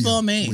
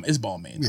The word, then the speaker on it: Balmain: She's like, it's not pronounced Balmain. – We all. – It's Balmain.